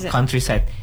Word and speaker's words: countryside: For example, countryside. You don't really have to read the U like count.